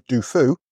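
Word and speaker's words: Du Fu: and author of In the Footsteps of Dufu. I pronounced that right? – Yeah, that's right.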